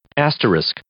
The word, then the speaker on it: asterisk: Asterisk. – You are correct. In one single try, you got it right.